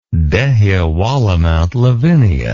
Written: Dehiwala-Mount Lavinia

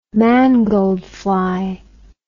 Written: mangold fly

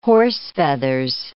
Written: horsefeathers

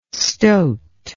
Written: stoat

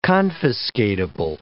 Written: confiscatable

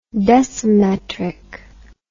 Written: decimetric

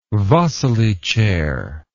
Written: wassily chair

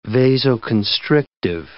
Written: vasoconstrictive

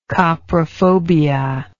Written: coprophobic

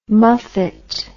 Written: muffet

